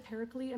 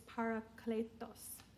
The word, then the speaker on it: parakletos: And we pronounce it paraklete. I mean, I guess that's just how we've been taught. But when I listen to the Greek word, it's parakletos.